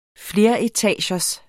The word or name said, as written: fleretagers